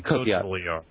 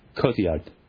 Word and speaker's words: Cotillard: Marion Cotillard. – Cotillard.